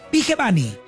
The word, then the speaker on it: Vigevani: Porque nadie sabía pronunciar su apellido. Entonces ya sabemos que es Fede Vigevani.